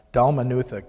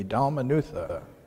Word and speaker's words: Dalmanutha: And he sent them away, immediately got into the boat with his disciples, and came to the region of Dalmanutha. I wanted to add an extra syllable. Dalmanutha. Can you all say with me, Dalmanutha?